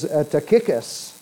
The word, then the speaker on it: Tychicus: Tychicus, and I had to look up how to pronounce this name because I've heard it pronounced a variety of ways, but it is Tychicus.